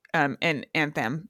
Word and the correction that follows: athame: Oh, and athame. – Athame, yes. I can never ever remember how to pronounce that.